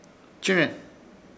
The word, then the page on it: Chɨ̃re: Ishir Chamacoco